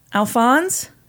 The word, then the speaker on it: Alphonse: I mean, Well, I don't know how it's pronounced. Spell it. It is spelled A L P H O N S E. Alphonse? – Alphonse.